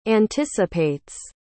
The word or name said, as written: anticipates